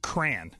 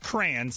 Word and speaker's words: crayon: It's not pronounced crayon, C-R-A-N. It's C-R, it's two syllables, cray-on.